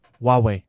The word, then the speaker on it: Huawei: So if you own a Huawei phone, apparently that's how you say it, by the way. – Huawei. – Oh, you say Huawei. – Yeah, apparently they don't pronounce the H. – Huawei. – Huawei, yep.